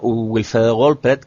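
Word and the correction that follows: Wolfredo Wildpret: que fue además el, perdón, Wolfredo Wilpert, siempre lo digo mal,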